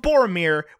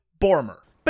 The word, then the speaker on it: Boromir: If an American was to read the name of Boromir without being told how to say it first, they would say Bormer.